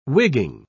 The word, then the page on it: wigging: British English